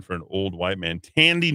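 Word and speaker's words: Thandiwe: This was Thandwe. Did I do that right? – I think it's Tandy Newton.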